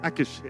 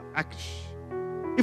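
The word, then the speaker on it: Achish: How do you pronounce this? Akish or Ashish? Akish, yeah, Akish.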